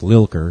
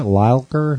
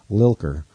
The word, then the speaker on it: Lilker: And I got to meet him and get his picture and find out that it's actually pronounced Lilker, because some people were saying, isn't it Lilker? And I was like, no, it's like Milker. It's like Milker with an L. Lilker.